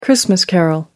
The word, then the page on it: christmas carol: American English